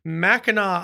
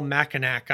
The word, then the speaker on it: Mackinac: You headed up to Mackinac Island, which I try to call Mackinac Island all the time because of the way it's spelled.